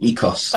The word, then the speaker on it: Ikos: So that's where the Ikos think a Greek distributor corrected us and told us that we should pronounce it Ikos.